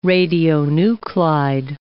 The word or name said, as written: radionuclide